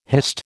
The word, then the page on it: hist: American English